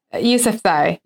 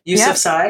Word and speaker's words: Yousafzai: Yousafzai, is that how she pronounces that? – Yousafzai. – Yousafzai. Yep.